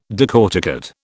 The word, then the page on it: decorticate: British English